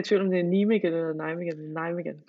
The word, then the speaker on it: Nijmegen: altså kan du udtale den klub der? Hollandsk klub? – Øh, var det ikke øh, Nijmegen? Er det ikke Nijmegen, NEC Nijmegen? Jo.